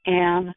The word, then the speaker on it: Anne: Some of my friends who do not speak English pronounce my first name Anne, but I'm used to Anne.